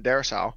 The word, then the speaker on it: Darrisaw: But then we traded down and drafted the offensive tackle, Christian Darrisaw. Is that how you pronounce his last name? – Yeah.